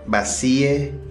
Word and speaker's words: vacíe: At security, you might hear, Por favor, vacíe sus bolsillos. Please empty your pockets. Vacíe, with that soft C, sounds like va-si-e.